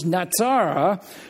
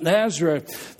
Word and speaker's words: Nazara: Nazareth is not really the right pronunciation. The Hebrew word for the town is Nazara, but of course they couldn't pronounce it. The Greeks couldn't pronounce it as that, and so they pronounced it Nazareth.